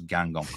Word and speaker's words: Guingamp: And you're the only guy I've ever, the commentators, who can actually pronounce Guingamp correctly, Dan, by the way.